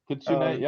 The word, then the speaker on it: Kitsune: You know, when you stumble onto a design that does catch an eye, like an Oni mask or a Kitsune, or if I'm saying that right. – Kitsune, yeah, you're right.